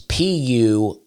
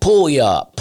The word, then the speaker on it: Puyallup: Puyallup is what it's called, but it's Puyallup. So it looks like it's like, pull you up, pull y'all up, pull y'all up. And I could not get around. I had to have somebody pronounce it for me because there's a y'all right in the middle, and I could not get around it.